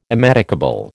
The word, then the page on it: immedicable: American English